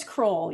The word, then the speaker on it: Croll: It's Croll.